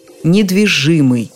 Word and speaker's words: недвижимый: Ударение всегда на первом «и», как и в слове «недвижимость». Для особо любопытных орфоэпический словарь под редакцией Аванесова считает законным и другой вариант – недвижимый. В принципе, существует возможность выбора.